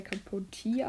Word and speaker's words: Capote: Und mein Platz Nummer 1 neben dem war Sommerdiebe von Truman Capote. Der ist auch so... Mhm. Letztens habe ich gesehen oder gehört, dass der Capote T ausgesprochen wird, weil er hat noch ein E hinter seinem T.